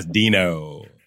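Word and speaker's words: Deno: I'm saying it right? Dino based. – Yes, Dino.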